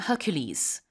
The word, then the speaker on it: Hercules: 그다음에 헤라클레스, 영어로는 Hercules.